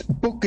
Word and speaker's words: Buck: Am I pronouncing your name correctly or is it Buck? – It's Book is the German name, and I'm originally German.